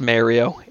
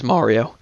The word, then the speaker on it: Mario: It's not pronounced Mario. It is Mario. – Mario. – It's not Mare like a horse. It's Mario.